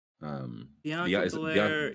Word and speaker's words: Bianca Belair: Is that am I saying her name right? She got the really long ponytail. – Bianca Belair, yes, WWE.